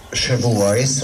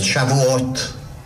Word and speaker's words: Shavuot: A mi hagyomány világunk pedig úgy ismeri, hogy Shavuot, ahogy mi kiejtjük, vagy Shavuot, ahogy Izraelbe kiejtik.